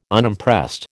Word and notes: Unimpressed: American English

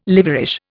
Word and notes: liverish: Indian

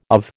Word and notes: of: American English

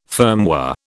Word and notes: firmware: British English

